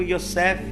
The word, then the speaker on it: Yosef: José, que quer dizer né, em seu nome é, de forma pronunciada judaico Yosef, nós vamos falar de Yosef hoje.